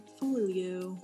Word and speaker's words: Forleo: We're in Everything is Figureoutable, Marie Forleo. – I'm so glad you say your last name. It's a toughie. – Folio. Like four and then Leo, like Leo DiCaprio.